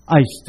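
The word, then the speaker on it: eis (εἰς): 这个介系词希腊文读作 eis， E-I-S， eis。这个字跟相信连在一起用，如果用英文来讲，你可以说 believe on，就相信一个人 believe on，或者相信一件事情，我们都 believe in。可是有的人觉得用 eis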